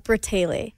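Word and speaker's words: Bratayley: Can you pronounce this for me? Bray-tay-lay? – It's Bray-tay-lay.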